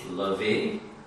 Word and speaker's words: LaVey: The first major form of modern religious Satanism was the Church of Satan, founded in San Francisco by, I hope I'm pronouncing his name correctly, Anton LaVey. LaVey, thank you.